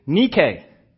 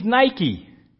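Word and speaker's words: nike (νίκη): That word in the Greek is nike. If you saw it written on paper, you would say, hey, he means Nike. N-I-K-E is pronounced in the Greek, nike. It is the Greek goddess of victory. That is what it means. N-I-K-E means victory.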